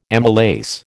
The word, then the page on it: amylase: American English